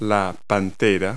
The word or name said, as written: panther